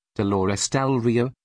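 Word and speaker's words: Dolores del Río: Dolores del Rio, Spanish pronunciation, Dolores del Rio,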